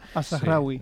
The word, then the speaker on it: Al-Zahrawi: Al-Zahrawi.